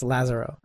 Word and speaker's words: Lazzaro: Nah, there's an accent on the A, so it's Lazzaro. – Okay. – It's like Lazarus.